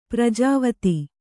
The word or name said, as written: ಪ್ರಜಾವತಿ